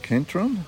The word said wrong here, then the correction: Kedron: Kedron, C-K-E-D-R-O-N, yeah.